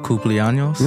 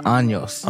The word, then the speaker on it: cumpleaños: Feliz cumpleaños. – Yeah, yeah. – Feliz cumpleaños. Did I say that right? – Cumpleaños. – Cumpleaños. – Años. – Años. – Yes.